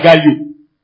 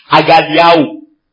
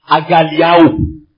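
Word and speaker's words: Agaliao (ἀγαλλιάω): I know you have been pronouncing it wrong. It's not Agalio. Agaliao. A-G-A-I-A-O. Agaliao.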